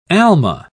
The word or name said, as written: alma